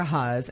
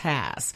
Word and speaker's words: Hass: And she always called me Rebecca Haas. And I finally was like, look. I don't call you Miss Sab because my name is pronounced Hass.